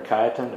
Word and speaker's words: Cajetan: In 1518, Luther was summoned to appear before Cardinal Cajetan, or Cajetan, I've heard it pronounced both ways.